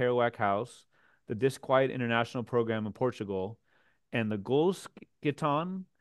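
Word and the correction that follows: Gullkistan: Did I pronounce that correctly? – Gulkistan, thank you.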